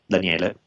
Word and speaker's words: Daniele: So my name is spelled like Daniel with an E at the end, Daniele. So D-A-N-I-E-L-E. My last name, Bolelli, B-O-L-E-L-L-I, again, B-O-L-E-L-L-I.